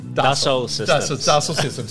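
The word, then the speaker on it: Dassault Systèmes: Dassault Systems. – There you go. See, it's Dassault. – See? – Yeah, see? Working for Dassault Systems.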